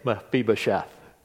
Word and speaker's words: Mephibosheth: It's quite a hard name to say, Mephibosheth. When you see it, you'll say, how do you say that? You just have to remember Phoebe, Mephibosheth, and you can say it.